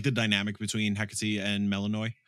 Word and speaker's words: Hecate: Hecate. – Yeah. – Which is, I don't know, thank you. Kelsey was like, you said it correct, because I made a note in my phone to make sure I pronounced it correctly.